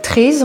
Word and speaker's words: Traezh: Et en fait, Trés en breton, ça s'écrit pas tout à fait comme... En français, c'est déformé l'orthographe. Trés, ça s'écrit T-R-A-E-Z-H. Ça veut dire la plage.